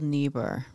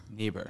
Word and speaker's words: Niebuhr: I believe it's Reinhold Niebuhr. – Niebuhr. – Yes.